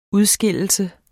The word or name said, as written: udskille